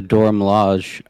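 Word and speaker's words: Dora Milaje: I mean, the Dor Milaje, is that how you pronounce it? We know that I can't pronounce things, and none of us know how to. – Yes, I believe you got it correct.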